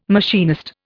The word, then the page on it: machinist: Indian